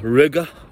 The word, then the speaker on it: Riga: If you guys remember, in Riga, which rhymes with nigga, I've been going to caffeine.